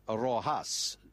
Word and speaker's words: Rojas: So do I pronounce it as Yas or Hus? Or Hus. Yes. Yes. Hus. Like a H. – Like in Alejandra Rojas.